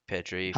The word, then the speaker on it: Pedri: think Barcelona's big advantage is they have Pedri. Is that how you say his name? – Oh my God! Yes, I, I forgot about him, man. That Pedri.